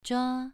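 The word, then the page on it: zhua: tone 2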